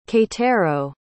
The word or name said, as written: keitaro